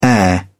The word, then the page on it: air: British English